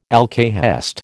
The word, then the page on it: alcahest: American English